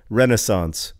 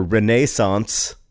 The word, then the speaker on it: Renaissance: And so I looked on How Jay Say, how you pronounce Renaissance, and he says Renaissance or Renaissance. And ever since then, whenever I hear the word Renaissance, I insist on like saying, you know, Renaissance is also an acceptable pronunciation.